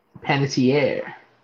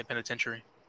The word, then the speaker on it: Panettiere: Panettiere. – Panettiere. I always say penitentiary. Panettiere.